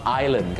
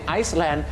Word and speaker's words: Island: Island ก็พอครับถ้าไปออกเสียง Iceland